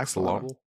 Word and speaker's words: axolotl: I think it's an axolotl. Is that not how you say it? – Oh, yeah, axolotl.